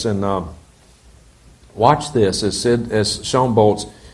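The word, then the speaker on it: Bolz: Sean Bowles, you attempt to say Bolts, but he actually pronounces it Bowles.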